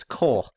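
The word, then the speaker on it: Kol: You're still mispronouncing his name. – I am, yes. – It's Cole. I remember having to have many off-mic conversations with you going, it's Cole. It's pronounced Cole.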